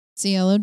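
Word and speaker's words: Cielo: CLO Drive.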